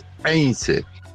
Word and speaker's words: Heinze: Bueno, Heinze también se pronuncia mal. Heinze se escribe con H y lo pronuncian todos, lo pronuncian mal. Es Heinze.